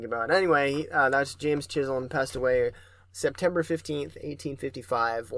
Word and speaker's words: Chisholm: It sounded like you said Chisholm whenever you pronounced it. And I was like, maybe I did. – I don't think it's pronounced that way. – Yeah, like the Chisholm Trail. – Yes, Chisholm, yeah.